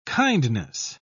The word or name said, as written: kindness